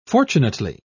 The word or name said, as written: fortunately